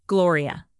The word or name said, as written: gloria